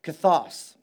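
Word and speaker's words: kathos (καθώς): Now, the word here, as, is a Greek adverb, and it's pronounced kathos, okay?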